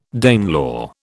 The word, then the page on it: Danelaw: British English